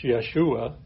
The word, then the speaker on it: Yeshua: Or for Joshua, it's Yeshua, which is Jesus' Hebrew name as well, which means the Lord is salvation.